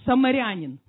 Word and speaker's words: самарянин: Самаритянин, самаринянин. В общем, самарянин.